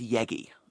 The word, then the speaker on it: Yegge: There's a chap called Steve, I believe his name is pronounced Yegi, Y-E-G-G-E, so we'll go with Yegi.